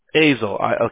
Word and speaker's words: Azle: So what we would do essentially kind of in a nutshell is anytime anyone's looking for the tree services and you're in, how do you pronounce it? The city is El or Azle? – Azle. – Okay, there we go.